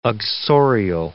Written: uxorial

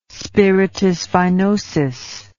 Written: spiritus vinosus

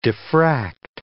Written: diffract

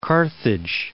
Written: Carthage

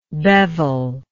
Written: beveler

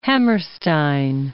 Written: Hammerstein

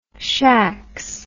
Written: shacks

